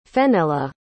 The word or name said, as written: fennella